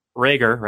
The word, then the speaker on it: Reagor: So, for instance, Jalen Reagor, right? Is that how you say it? – Yes.